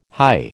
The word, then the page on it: high: American English